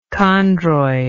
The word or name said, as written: chondroid